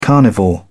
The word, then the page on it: carnivore: British English